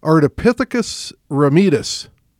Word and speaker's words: Ardipithecus ramidus: And so this guy is pronounced Ardipithecus ramidus. – Yes. – How's that? – That is perfect.